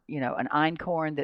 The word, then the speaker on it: einkorn: I'm not saying they're the only ones, but the ones you hear about are einkorn. And that's einkorn. – A German word, right? Einkorn. And emmer, E-M-M-M-E-R, and then spelt, which is spelt, just like it sounds.